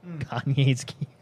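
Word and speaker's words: Kanai's Cube: Kanye's Cube is a new artisan that allows Kanye's play. Cube? – K-A-N-A-I-S. Kanye's Cube.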